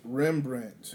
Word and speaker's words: Rembrandt: Oh, Remembrant. – Rembrandt. Yeah. No, I'm probably butchering it. But yeah, Rembrandt.